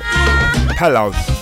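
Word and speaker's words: Palo!: They're called Palo. I spell it for you. It's P-A-L-O, exclamation mark. So, you know, a straight line with a dot under it. Palo. This track is called El Tarru. T-A-R-R-U.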